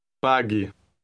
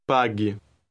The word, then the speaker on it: pague: Listen and repeat word 15. – Pague. Pague. – Note that the U is silent.